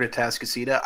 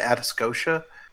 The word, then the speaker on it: Atascocita: Gerald, if it makes you feel any better, the first time I saw the word Atascocita, I called it Atascocia.